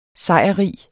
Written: sejrrig

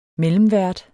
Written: mellemvært

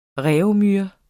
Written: reaumur

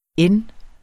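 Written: n'te

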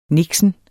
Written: niksen

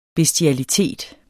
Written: bestialitet

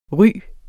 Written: ry

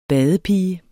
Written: badepige

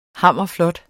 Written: hammerflot

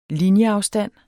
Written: linjeafstand

